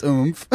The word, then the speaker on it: Oumph!: perfect audience for this new Swedish company called Oomph. I think that's how it's pronounced. – Oomph. – O-U-M-P-H exclamation point. – It's Oomph.